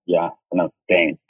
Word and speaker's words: Zhang: My name there is R-A-Y, Ray, and the last name is Z-H-A-N-G. – Wonderful. – So it's pronounced Zhang.